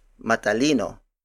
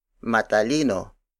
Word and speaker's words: matalino: To use it as an actual adjective, we add the MA syllable at the beginning, so it becomes matalino. Matalino. Matalino. Matalino is spelled as M-A-T-A-L-I-N-O.